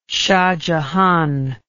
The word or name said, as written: shahjahan